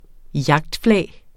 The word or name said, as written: yachtflag